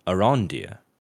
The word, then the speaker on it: Arondir: But then we have our next shot here of an elf warrior named Arendir, or maybe I pronounced it wrong, Arondir. That actually sounds like it makes more sense.